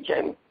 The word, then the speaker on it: Jamie: Spell your first name for me, Jamie. – It's just J-A-M-I-E.